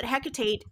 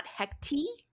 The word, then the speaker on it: Hecate: And it isn't it is pronounced Hecate, not Hecti, which has become much more popularized lately in the last four or five years.